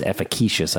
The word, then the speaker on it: efficacious: It's actually pronounced efficacious. I mispronounced it.